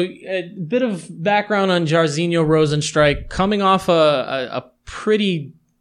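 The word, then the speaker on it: Jairzinho: Let's start off with Junior Dos Santos versus Yair Rozenstrike. I feel like I could say that guy's name five different times. – You said his first name wrong. – Yair? – It's Jarzinho.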